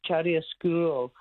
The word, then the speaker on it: chiaroscuro: I don't know if I pronounce it properly, but Chiaroscuro. It was what I actually phoned up for. – Oh, look, your pronunciation's beautiful.